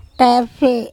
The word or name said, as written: TEphe